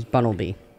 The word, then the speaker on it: Bunnelby: That's how his name is pronounced, is Bunnelby.